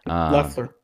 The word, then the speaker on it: Loeffler: Loeffler. – Yeah. Man, I always mess it up because I don't know why. It's like Loeffler, Loeffler, and it's something else.